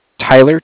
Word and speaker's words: Tyler: My name is Tyler, T-Y-L-E-R. I'm calling with Cardiff. It's spelled C-A-R-D-I-F-F, as in Frank.